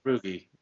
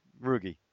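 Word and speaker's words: Rougned: We've got Javier Baez, Jimon Choi, Carlos Correa, Renato Nunez, you pronounce it properly, Odor, the second. – Yeah, Rugi. – Rugi, thank you.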